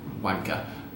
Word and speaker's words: Guanca: It's spelled G-U-A-N-C-A, but it is pronounced Wanka.